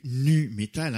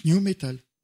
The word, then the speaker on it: nu metal: Alors, c'est pas du nu metal, hein, c'est pas du metal où il y a poil, hein, c'est du new metal. – Non, il y a pas. – Oui, mais ça se prononce new metal.